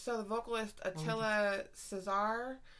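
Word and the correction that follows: Csihar: It just says right here how to pronounce his fucking name. Shihar. So, Attila Shihar.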